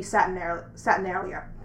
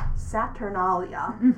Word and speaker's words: Saturnalia: Yeah, one solstice festival from antiquity that I don't personally incorporate, it's more Roman than Greek, but which would be Saturnalia. I'm pronouncing that wrong. Saturnalia, which is obviously a feast to the god Saturn, who's equated with Kronos in Greece.